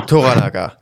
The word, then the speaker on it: Toranaga: Toranaga, genau. So ist es ausgesprochen, richtig. Und in seinem Fürstentum oder in seiner Lordschaft,